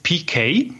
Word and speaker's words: Peykay: Ja, die Band heißt PK, das schreibt man P-E-Y-K-A-Y.